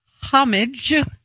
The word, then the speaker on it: homage: He said there are three pronunciations. Say the first one. – There's homage. – The way I said it. This is the way you say it.